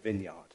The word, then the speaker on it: vineyard: And a field of vines is called a vineyard, which is spelt vineyard, but they pronounce it vineyard, okay?